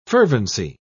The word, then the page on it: fervency: British English